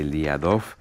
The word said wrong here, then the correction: Liadov: Eso es, Lo he dicho bien, ¿no? Era Elia Dov. – Liadov,